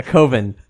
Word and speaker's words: coven: It rhymes with oven. – Yes, a coven.